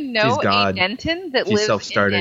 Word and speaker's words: Denton: Although everybody says the town Denton, and whenever he introduced himself to me, he said Denton, so he made sure to t- pronounce the T.